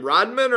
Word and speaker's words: Raudman: Raudman or Raudman? – Yep. You got it right the first time. Like Dennis Raudman, the old basketball player. Just spelled a little different.